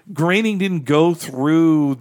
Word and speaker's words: Groening: Groening sort of guiding rules. – I think so too. Yeah. And part of that, we heard that Groening rhymes with complaining thing